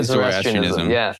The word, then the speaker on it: Zoroastrianism: Zoroastrianism. – That's it. Yeah, Zoroastrianism.